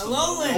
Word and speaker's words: Alolan: Is that how it's pronounced? – Alolan. – Alolan. – Okay.